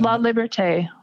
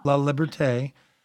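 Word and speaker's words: LaLiberté: Well, with Kathleen Liberté, LELIBERTE, with a slash at the end of it, I cannot pronounce that appropriately, so I don't want to play it. It's La Liberté. – La Liberté.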